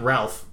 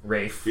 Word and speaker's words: Ralph: Not Ralph, Rafe.